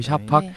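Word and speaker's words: Şafak: 그 작년 런던 도서전 같은 경우에는 세계적인 베스트셀러 소설가인 미국 작가 윌리엄 보이드 작가라든지 아니면 터키의 여성 소설가 발음 잘해야 됩니다. 엘리프 샤팍이에요. 샤팍. – 오, 예, 어, 엘리프 네. – 샤팍. 네. 발음 잘못하면 큰일 납니다.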